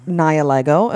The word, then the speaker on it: Nihilego: The last time we recorded, we talked about how they may or may not have been releasing Nihilego, as we have since learned that it is pronounced.